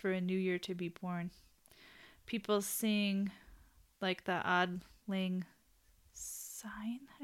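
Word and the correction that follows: Auld Lang Syne: I never say that right. May all acquaintances. – Old ling sign. – Yeah, that sounds right. Old, like a u l old Auld, sign. sign.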